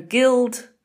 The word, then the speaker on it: guild: So guild is a hard G, even though it's followed by an I.